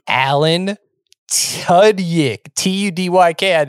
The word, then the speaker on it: Tudyk: T-U-D-Y-K.